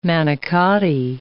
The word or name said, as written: manicotti